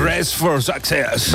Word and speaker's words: Dress for Success: Hazlo tú, que eres más inglés. – Dress for Success. – ¿Ves? A que le sale mucho mejor.